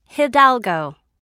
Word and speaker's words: Hidalgo: It's Hidalgo. – Okay, how do you spell that? Is it H-I-D-A-L-G-O? – Yes, very good.